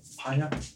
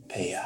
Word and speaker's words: Peya: The names of the 2 that you have acquired in this deal are Volmir and Peya. – Paya? – Peya. – How do you spell it? I'm asking a third. – P-E-Y-A. – P-E-Y-A. And Volmir.